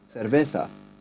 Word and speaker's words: cerveza: He has a more, like, so he pronounces things with, say, cerveza, where the C is pronounced more as a th.